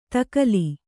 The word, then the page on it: ತಕಲಿ: Kannada